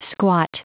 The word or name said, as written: squat